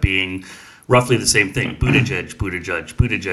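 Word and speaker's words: Buttigieg: The guy who's actually running for president and not his husband insists that it's pronounced boot edge edge. That's how he in his Twitter bio says that you should say his name, boot edge edge.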